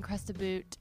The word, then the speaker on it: Crested Butte: And then I'll be in Crested Butte, Colorado.